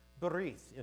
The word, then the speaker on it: Berith: Berith in Hebrew, the way I like to pronounce it. I tend to use the Ashkenazic pronunciation. Most people pronounce it bris, a Sephardic pronunciation.